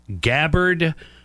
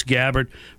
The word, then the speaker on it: Gabbard: I believe it's Gabbard, how it's pronounced. I could be wrong, but I believe it's Gabbard. Remember, it like rhymes with scabbard.